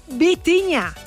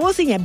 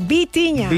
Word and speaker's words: Vitinha: Seguimos con Vitinha. No Bocinha, Vitinha.